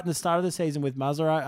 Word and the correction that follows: Mrazek: How do you say his name? – Mrazek.